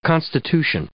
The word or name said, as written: constitution